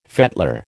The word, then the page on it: fettler: American English